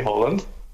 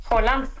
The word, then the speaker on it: Haaland: Where can you say Haaland? – Haaland? – Yeah, can you say his name? – Haaland? – Yes.